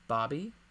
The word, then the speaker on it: Bobbie: That was directed by Marianne Elliott, starred Rosalie Craig as Bobby, B-O-B-B-I-E. – How is it pronounced? – Bobby. – You said Bobby.